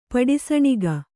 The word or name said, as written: ಪಡಿಸಣಗೈ